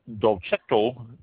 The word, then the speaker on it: Dolcetto: It's spelled D-O-L-C-E-T-T-O, Dolcetto.